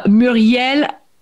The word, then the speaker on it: Muriel: Muriel, there is a more accentuation on the L.